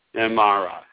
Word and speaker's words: Imara: Is Amara the correct pronunciation? – Amara. I-M-A-R-A. – Amara.